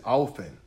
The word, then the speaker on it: often: I said often. The T is silent.